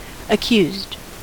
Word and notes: accused: American English

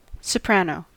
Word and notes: soprano: American English

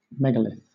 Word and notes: megalith: British English

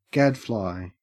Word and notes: gadfly: Australian English

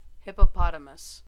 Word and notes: hippopotamus: American English

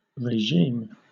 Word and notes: regime: British English

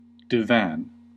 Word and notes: divan: American English

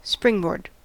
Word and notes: springboard: American English

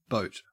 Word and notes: boat: Australian English

